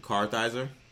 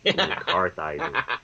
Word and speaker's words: Kartheiser: Vincent Kartheiser. He was in Mad Men. – Spell that way, sorry. – Kartheiser.